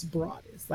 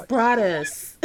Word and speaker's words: Broaddus: And you know, people call me Broaddus, and I'm like, and my mother, God rest her soul, like it's Broaddus, like it's Broaddus. Well, I will say, I will credit Snoop Dogg for knowing how to pronounce it, because you know him being Calvin Broaddus.